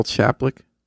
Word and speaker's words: Chaplik: How do you say Miral Chaplik? How do you spell that? – Well, Miral is Miriam, M-I-R-E-L, Miral Chaplik, however you want, C-H-A-P-L-I-K.